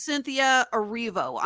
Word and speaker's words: Cynthia Erivo: It's Cynthia Erivo. I'm sorry I mispronounced her name.